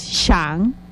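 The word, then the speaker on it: Xan: Y la última, que es ya el nombre del árbol, que es Shan, que es Shan, que se escribe con la letra X.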